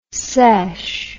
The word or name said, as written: sesh